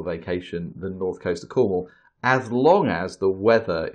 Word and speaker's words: Cornwall: I was looking forward to you try and pronounce Cornwall or whatever you call it. – No, Cornwall, that's very good.